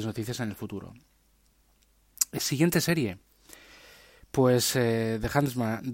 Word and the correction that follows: The Handmaid's Tale: Handman's, The Handmaid's Tale, perdón, porque la pronunciación ahí me ha salido mal.